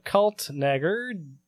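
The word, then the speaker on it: Kaltenegger: So, study lead author Lisa Kaltnager, director of Carl Sagan Institute at Cornell University. – cancel us? – No, it's like Schwarzenegger. – Oh, what? – It's spelled the same way, you douchebag.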